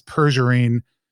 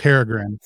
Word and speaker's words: Peregrine: Tell us a little bit about St. Peregrine, which I always want to pronounce Pergerine, but it's not. – Not at all. – It's Peregrine.